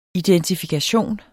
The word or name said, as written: identifikation